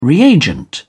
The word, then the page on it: reagent: British English